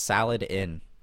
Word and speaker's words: Saladin: Lord Saladin? It's Saladin. Saladin.